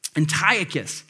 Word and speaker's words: Antiochus: Antiochus. I need to like put the phonetic in my notes, but Antiochus is the Roman ruler who came into Jerusalem, and this is known as the Abomination of Desolation.